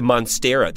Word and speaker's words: Monstera: A Monstera. And then you know, sorry, did you mispronounce monster? – No, it's a Monstera.